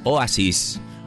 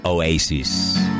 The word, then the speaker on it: Oasis: Es Oasis, o como dicen los que pronuncian bien, Oasis.